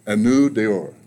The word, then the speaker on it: Anneau d'Or: And now let me say the name of the horse, and correct me if I say it wrong. Anneau d'Or. – Yeah, that's how we pronounce it. I'm not even really sure if that's the right way to do it, but that's how we say it.